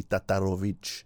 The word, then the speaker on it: Tatarowicz: It's me, Niko Tatarovic. I always say my name in a funny way, don't I? It should be Tatarovic.